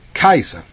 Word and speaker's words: Caesar: In Latin it's pronounced like Kaiser, with a hard C. But we anglicize it by pronouncing a soft C.